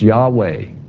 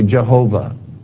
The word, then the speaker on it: Yahweh: We don't know how to pronounce it, but it's Yahweh. YHWH, or some would say Jehovah.